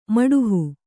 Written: ಮಾಡುಹ

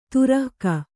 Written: ತುರಕ

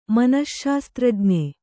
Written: ಮನಶ್ಶಾಸ್ತ್ರಜ್ಞೆ

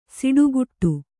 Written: ಸಿಟ್ಟುಗುಟ್ಟು

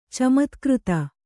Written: ಚಮತ್ಕ್ಕøತ

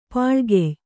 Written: ಪಾಳ್ಗೆಯ್

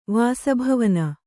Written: ವಾಸಭವನ

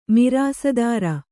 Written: ಮಿರಾಸಿದಾರ

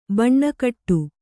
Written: ಭಾನಗೇಡಿ

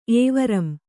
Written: ಏವರಂ

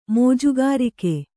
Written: ಮೋಜುಗಾರಿಕೆ

